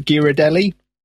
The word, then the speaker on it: Ghirardelli: Is it the correct pronunciation? The Ghirardelli? – Yep.